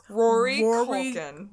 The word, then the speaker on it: Culkin: Rory Culkin. – Rory Culkin. Because I want to call him McCulkin. Because I'm thinking of Macaulay McCulkin. Macaulay Culkin.